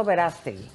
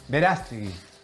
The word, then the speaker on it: Verástegui: No, Eduardo Verástegui. – Verástegui.